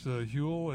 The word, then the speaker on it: Huel: You catch Huel? It's from England. Rhymes with gruel, rhymes with fuel.